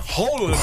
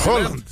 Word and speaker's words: Haaland: Então, não é Haaland que se escreve, é Holland. Holland.